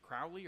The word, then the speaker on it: Crowley: And then the other guy that I know almost nothing about, too, is it Crowley or Crowley? Aleister Crowley? – So it rhymes with holy, as people will Crowley. – Say. – Okay. – So it's Crowley, yeah.